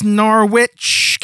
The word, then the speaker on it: Norwich: No, it's Norwich. – Norwich. – Norwich. – Norwich. That sounds like porridge. – Yes. Garage, porridge, Norwich. That's how they all go.